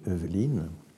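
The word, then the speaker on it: Heuveline: Et lui, il l'avait, donc ça s'écrit H-E-U-V-E-L-I-N-E, e e, Eveline.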